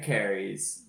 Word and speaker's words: Kehres: K-E-H-R-E-S, and Larry is just Larry. – How many titles did he win? – Larry Carey's, which is the correct way to pronounce his name in this podcast, won 11 D3 titles from 1993 to 2012.